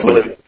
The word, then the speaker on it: Blut: Draken Blut. – Yeah, Blut. – That was close. Just long U rather than a short U.